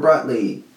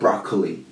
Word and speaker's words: Brotli: Enable gzip or Brotli. You know, there's a lot of stuff. Not broccoli, Brotli. I think that's how you say it, Brotli, compression.